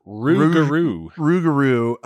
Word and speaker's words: rougarou: Rougarou.